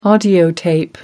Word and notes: audiotape: British English

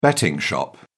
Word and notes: betting shop: British English

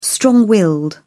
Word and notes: strong-willed: British English

